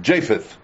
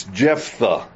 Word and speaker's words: Jephthah: For my whole life, I have mispronounced his name. I have been calling him Japheth, and it's Jephthah.